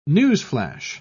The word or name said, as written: newsflash